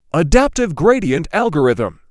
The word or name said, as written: adaptive gradient algorithm